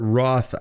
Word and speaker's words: Roth: Well, it rhymes with moth and it starts with an R. – Well, yeah, for the tax implications, I would say a Roth IRA or a Roth 401k, something that doesn't have those big tax implications.